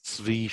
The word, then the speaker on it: Zvi: My name is Zvi Schreiber. Zvi is spelled Z-V-I.